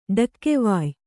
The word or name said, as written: ಡಕ್ಕೆವಾಯ್